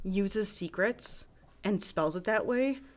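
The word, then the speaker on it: Seacrets: Okay that is called Secrets, but it's called like you know like Seacrest, like Ryan Seacrest.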